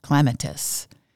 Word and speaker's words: clematis: Clematis does not rhyme with running at us or window lattice. It should be said clematis.